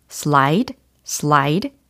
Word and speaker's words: slide: 발음은 슬라이드 아니겠죠? 슬라이드 이거겠죠? 슬라이드 slide, slide.